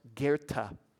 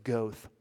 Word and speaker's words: Goethe: One of them was, she mispronounced Goethe, the German author, which I don't know if that's right, so maybe I'm out already. Goethe, it looks like Goethe, but it's not.